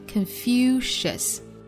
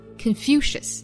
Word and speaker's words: Confucius: 那我们还要知道孔子用英语怎么说，名字叫做 Confucius， Confucius，尤其要注意最后一个音节是刷 sound， us，不能读成了卷舌的二哈，应该是 Confucius。